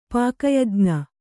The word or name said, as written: ಪಾಕಯಜ್ಞ